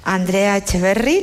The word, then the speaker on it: Andrea Echeverri: Andrea Echeverri, lo he dicho bien, sin acento.